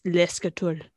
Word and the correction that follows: l'escalier: de l'escalier. – Oh goodness. There's no T. L apostrophe E C E S C A L I E R. L'escalier.